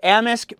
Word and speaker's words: Amisk: Oh, it's the S that gets me. I told you I'd mispronounce it. The mayor of Amisk, Bill Rock.